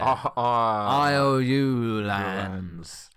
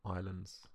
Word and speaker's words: Islands: And you said islands in a really bizarre way there. IOU lands. – Well, I wasn't sure whether it was Isles or. Islands. Islands. – That shouldn't affect how you pronounce the Isle part of either of them.